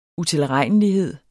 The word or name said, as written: utilregnelighed